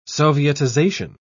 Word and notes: sovietization: British English

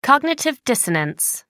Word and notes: cognitive dissonance: American English

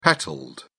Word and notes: petalled: British English

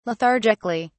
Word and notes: lethargically: British English

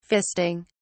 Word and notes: fisting: British English

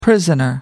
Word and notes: prisoner: American English

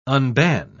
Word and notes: unban: British English

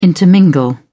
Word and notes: intermingle: British English

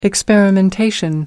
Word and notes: experimentation: American English

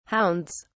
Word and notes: hounds: British English

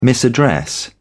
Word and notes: misaddress: British English

